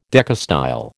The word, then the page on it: decastyle: American English